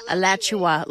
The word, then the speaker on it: Alachua: Alachua, is that how you say it? – Alachua, like Joshua, but Alachua.